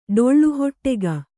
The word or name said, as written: ಡೊಳ್ಳುಹೊಟ್ಟೆಗ